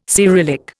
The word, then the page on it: Cyrillic: Indian